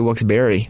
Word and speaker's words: Wilkes-Barre: The second half is spelled B-A-R-E, but people say Wilkes-Barre, but it's actually Wilkes-Barre.